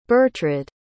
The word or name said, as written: Bertrade